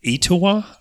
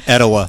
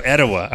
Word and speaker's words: Etowah: We are sitting here in Etowah? I hope I'm saying that right. – You're not. – Well, how do you say it? – Etowah. – Oh, Etowah. I'm sitting here in Etowah,